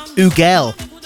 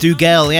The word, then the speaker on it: Hugel: Found out today it's Ugel. – Is it? – It's pronounced Ugel, yeah. And that's from Ultranate's official Instagram. – Oh, I call him Hugel as well. – DJ Ugel. – Oh. – Drop the H, Ugel.